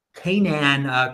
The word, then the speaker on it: Canaan: Let's talk about Canon. I'm mispronouncing their name. Canaan Creative, which is a miner that says they want to have an IPO in the United States.